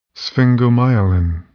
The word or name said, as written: sphingomyelin